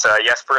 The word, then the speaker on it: Jesper: Oh my gosh, you got to enunciate your J's as silent J's. It's Jesper Eliasson, and he plays in the Vekra, it's called.